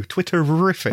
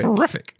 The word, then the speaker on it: Twitterific: You got to say it like your Scooby-Doo, Twitterific. – Terrific, yeah.